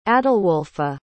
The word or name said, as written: adalwolfa